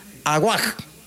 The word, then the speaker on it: Awak: La señorita o señora Diana, yo pronuncio como U, la W intermedio. Aguac. – Aguac. – Sí, A-W-A-K.